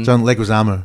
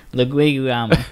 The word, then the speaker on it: Leguizamo: what's his surname? John Leguizamo. – There you go, that's how you say it. – Leguizamo.